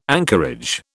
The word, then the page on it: anchorage: British English